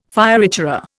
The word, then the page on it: fioritura: Indian